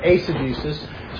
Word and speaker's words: eisegesis: If you think of the letter eight or the number eight, EIGHT is A, so it actually be pronounced eisegesis.